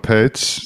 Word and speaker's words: Paetz: Well, let me introduce you to Jono Paetz.